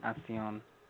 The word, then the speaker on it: Alcyone: Halcyon with an H, H-A-L. – A-L-C-Y-O-N-E. – Oh, okay. – Yeah, it's pronounced, it's spelled differently, but it's actually Halcyon.